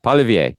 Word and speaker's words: Poilievre: Poilievre, yeah.